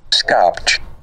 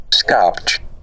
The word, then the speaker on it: Skopje: Skopje. – I'm sorry? – Skopje.